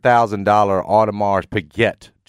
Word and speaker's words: Audemars Piguet: Have you ever, you know, he got a $300,000 Audemars Piguet, John has told me is the way you pronounce that watch that Meg bought him.